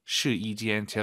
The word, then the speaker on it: 试衣间: 숫자 1이 아니라 여기서는 yi 발음, 试衣 아니고 试衣. 시이지엔. 제가 이렇게 왜 말씀을 드리냐면 한국인 초급자분들이 가장 어려워하는 게 사성 다음에 일성이에요. 따지아 이 발음을 따지아 막 사성이 일성이 돼버려요. 네, 그래서 시이지엔 확실하게 시이를 확 올려서 발음하셔야 됩니다.